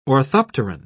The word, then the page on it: orthopteran: British English